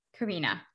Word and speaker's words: Carina: Carina. – Carina. Okay, great. – Carina Alyce, yeah.